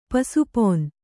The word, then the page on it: ಪಸುಪೊನ್: pasu pon